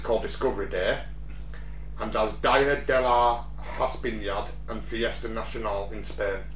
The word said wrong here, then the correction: Hispanidad: Día de la Hispanidad. – I love it when we're asking you to pronounce... – Hispanidad? Día de la Hispanidad. – Día de la Hispanidad, yeah.